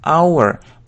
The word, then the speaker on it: hour: Здесь начинается на согласную «h», как бы, но она не произносится. Поэтому здесь будет именно «an hour», потому что смотрим с вами на произношение «our», звук «our», «our» — это согласный звук, поэтому «an hour», только так, «an hour», «час», «an hour», то же самое и здесь.